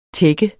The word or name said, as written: tække